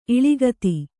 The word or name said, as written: ಇಳಿಗತಿ